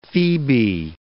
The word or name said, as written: phoebe